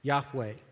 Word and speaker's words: Yahweh: capital L-O-R-D. This is put in place of the Hebrew tetragrammaton Yod-Heh-Vav-Heh, or Y-H-W-H, often pronounced Yahweh.